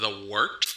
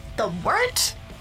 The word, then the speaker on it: wort: Hey, moron, it's pronounced The Wort. – What the hell is a wort? – It's pronounced The Wort. – What is it? The Wort? Yeah, okay, I'll do that again. This is The Wort on Govs Radio. – It is The Wort, not warts, not fort, not short, The Wort.